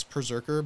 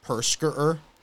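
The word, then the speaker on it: Perrserker: It's Perserker, but Perserker. – I didn't play him. – I prefer to pronounce it Perskerer. – Perskerer. – Because that was how I read it the first. I was like, Perskerer? And it kind of just stuck with me.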